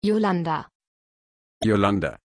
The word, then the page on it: Yolanda: German